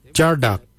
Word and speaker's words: Djardag: Jindal was spelt D-J-E-N-D-A-L, Jindal. Then we'll look at Jardak. Jardak is grey, Jardak. Jardak is spelt D-J-A-R-D-A-G, Jardak, grey.